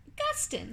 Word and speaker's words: Gustin: Gustin? – Gustin, like Justin with a G.